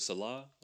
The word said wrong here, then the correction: Sela: Yeah, Sela. – Sela, excuse me, Sela.